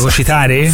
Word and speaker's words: fagocitare: ti fai un po' fagocizzare dalle situazioni. – Fagocizzare? – Non si dice fagocizzare. – Fagocitare? – Quello lì.